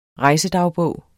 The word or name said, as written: rejsedagbog